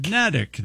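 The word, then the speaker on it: Neddick: It rhymes with medic, according to this. – Nedic. – Nedic, then, not Nedic. – It sits on the small Nubble Island. – What? – Nubble. N-U-B-B-L-E.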